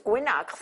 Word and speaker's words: Wenax: Ahora no me digáis, lo has pronunciado mal. No, es Guinax.